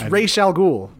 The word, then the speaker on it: Ra's al Ghul: It's Ra's al Ghul.